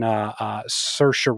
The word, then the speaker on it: Saoirse: But basically, this is the story of, it's Paul Mescal and Saoirse Ronan, who I can say her name.